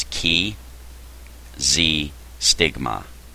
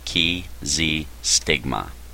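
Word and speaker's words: chi xi stigma: It's ki z stigma. Okay? That's C H I ki. And then another word z, which is actually X I, and then the word stigma. Ki z stigma. Ki, C-H-I, is actually a letter of the Greek alphabet.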